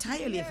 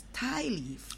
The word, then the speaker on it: tie leaf: I use that in my mind as understanding, say tire leaf, but it's not tire leaf, it's tie leaf. – Oh, tire leaf. – That's in T-I-E, when you tie.